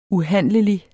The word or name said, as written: UHANDLELIGT